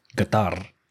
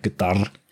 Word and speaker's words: Qatar: But this year's event, held in Qatar, which is actually not the right way to pronounce it. It's more like Qatar, but we're not going to pronounce it that way at all in this episode.